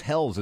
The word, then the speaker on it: Helles: The Fresh as Hells. Is that how you pronounce it? Yeah, yeah.